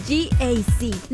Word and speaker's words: GAC: No se dice GAC, se dice GAC. Nuevamente, GAC, y se maneja mejor de lo que se pronuncia.